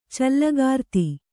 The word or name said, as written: ಚಲ್ಲಕಾರ್ತಿ